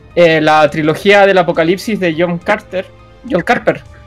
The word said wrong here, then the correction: Carpenter: Carpenter, uy Oye, que estoy mal, malo para pronunciar.